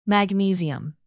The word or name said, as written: magnesium